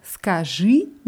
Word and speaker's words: скажи: Und im Imperativ heißt es скажи mit Betonung auf dem letzten I.